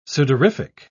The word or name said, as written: sudorific